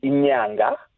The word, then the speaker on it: inyanga: All right, a month would be inyanga, which is also a homonym with the moon, because it's the lunar month.